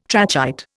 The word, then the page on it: trachyte: Indian